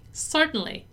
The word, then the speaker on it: certainly: Certainly, what can I help you with? All right, so don't forget to not really pronounce the part tang, okay? Just kind of eat that syllable and then say certainly, all right?